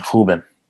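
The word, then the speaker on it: Rúben: Then Ruben Dias, the Portuguese pronunciation, Ruben.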